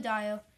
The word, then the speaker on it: Adebayo: But, yeah, I mean, Jimmy Butler and Tyler Hero, and I think Bam Adebayo, I think I'm saying it right. Bam Adebayo? Yeah, Bam Adebayo.